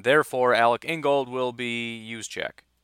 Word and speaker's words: Juszczyk: But since we hired LaFleur, everybody's mind went to Kyle Juszczyk, is how you say his name, I believe. Looks like Juszczyk.